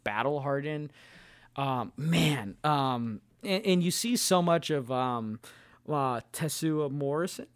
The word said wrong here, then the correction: Temuera Morrison: Is that how you say his name? Right, Temuera Morrison.